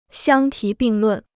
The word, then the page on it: 相提并论: xiāng tí bìng lùn